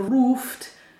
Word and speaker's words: Ruft: We have L-U-F-T and R-U-F-T. – Yeah, but they are pronounced differently. In Luft, we have a short, opened U sound, O, Luft. And in Ruft, this comes from Rufen, from the word Rufen, where it's a long and closed U sound, Rufen.